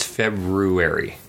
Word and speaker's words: February: Like, there's way too many R's. – Are you supposed to pronounce that first R? – It's February.